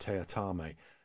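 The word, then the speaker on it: Teatime: where Mark Warren is also in it as a character called Tea Time, only he insists it's pronounced Teatame.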